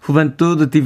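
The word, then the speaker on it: Juventud: 음악을 들어봤더니 제 발음이 비슷하긴 했군요. 파커 이바네즈의 후벤투드 디비노 데소로라고 어, 노래했습니다. – 음. 저는 유루이태리의이 발음 비슷하게 유벤투스 이렇게 했는데 후벤투드 예, 이렇게 발음을 하는군요.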